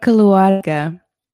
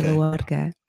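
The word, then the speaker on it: Keluarga: It's called Kaluarga. And it means Kaluarga. Yeah. With like a rolled R, I guess, if you can. Okay. Kaluarga. It's kind of how it looks. It's kind of pronounced that way.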